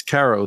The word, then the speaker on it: Carro: So he is the CEO of, is it Caro, or am I saying it right, Caro? – Yeah, it's Caro. That's correct.